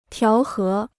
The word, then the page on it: 调和: tiáo hé